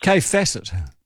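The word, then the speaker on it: Kaffe Fassett: Kefacet. – Yeah, yeah. So it's spelled I just wanted to, for everyone, K-A-F-F-E, Kafe, Facet, F-A-S-S-E-T-T.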